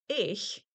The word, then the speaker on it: ich: Ich, I-C-H, and ich, I-G, make a white noise, leaky balloon sound. Not itch, ick, or ig. Some regions pronounce this as ish.